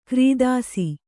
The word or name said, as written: ಕೀರ್ತಿಸು